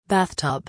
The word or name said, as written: bathtub